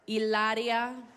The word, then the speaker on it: Hilaria: Do you guys know who Hilario Baldwin is? – She grins cheekily. – I'm saying it wrong. I'm sorry. Hilaria Baldwin.